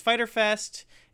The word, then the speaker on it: Fyter Fest: So everyone, if you're listening to this and you don't understand what that is, even though it has a Y in there, it might be confusing. It's just Fyter Fest. It's spelled funny.